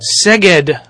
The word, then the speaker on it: Szeged: You guys, well, some of you just got back from Szeged, Hungary.